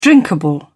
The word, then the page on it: drinkable: British English